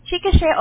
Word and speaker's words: Chickasha: Chickasha, Oklahoma.